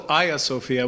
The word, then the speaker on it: Hagia Sophia: We all say Hagia Sophia, but in Turkish, you'd say Hagia Sophia, right? – In Turkish, it's called Hagia Sophia, which is Turkified version of the original name.